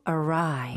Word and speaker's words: awry: 스펠링도 주의하셔야 돼요. 이거는 눈으로 보시는 게 좋은데요. 45페이지에 A-W-R-Y. – 아하. – Go awry. – 이거 잘못 보면 awry인가? 라고 아니면 awry인가? 라고 엉뚱하게 읽을 수 있을 것 같아요.